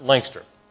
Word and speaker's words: Lancaster: Now, I know it's spelled Lancaster, but up there they pronounce it Lancaster.